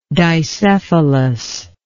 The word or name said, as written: dicephalism